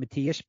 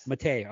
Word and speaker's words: Matej: M-A-T-E-J. Is it Matej Penaz? – Matej, I believe it is. – Matej.